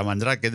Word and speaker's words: Mandrake: Pero es que por el año 98 aproximadamente, año arriba, año abajo, cayó en mis manos una distribución que en español era Mandrake, no Mandrake, lo siento, pero no, sabía pronunciarlo bien, era Mandrake, de leído tal cual, que con su KDE1 me hizo descubrir este mundo del software libre.